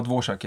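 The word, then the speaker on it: Dvořák: Ska jag uttala mig det? Dvorak? – Ja, Dvorak. Ja. Dvorak stavas det ju.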